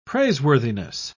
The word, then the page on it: praiseworthiness: British English